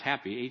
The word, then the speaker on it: Hapi: And so the first God that we'll be addressing today is the God called Hapi. That's how it's pronounced, Hapi, H-A-P-I.